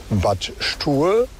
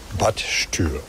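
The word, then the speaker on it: Bad Stuer: Und etwas weiter südlich gibt es den Ort Bad Stur. Der wird mit UE geschrieben und deshalb sagen die Leute häufiger Bad Stür.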